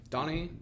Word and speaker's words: Donaghy: It was literally just them talking about Tim, how do you pronounce his last name? Donaghy.